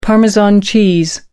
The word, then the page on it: parmesan cheese: British English